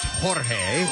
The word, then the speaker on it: Jorge: George or Jorge. – Jorge! Yeah, Jorge! – Pronounced Jorge.